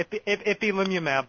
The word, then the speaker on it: Ipilimumab: is it Ipilimumab? Sorry. – Yeah, Ipilimumab, but I get a lot more practice saying it than you do.